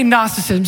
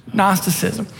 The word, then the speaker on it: Gnosticism: It's G-N-O-S-I-S. So the G is silent. In our language, we say knowledge. The K is silent when we say no. So say Gnosticism. Say it with me.